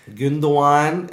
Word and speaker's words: Gündoğan: Gündoğan, er hat es in der Folge Ich habe es in der Folge tatsächlich falsch ausgesprochen.